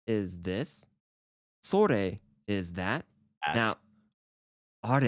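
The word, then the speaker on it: Sore (それ): Sore, pronounced so-re.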